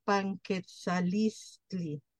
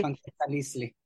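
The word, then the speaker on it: Panquetzaliztli: emocional y pues hasta visual del tiempo de Panketzaliztli. Para empezar, ¿lo dije bien? – Sí, sí, lo dijiste bien. – Ok. – Panketzaliztli.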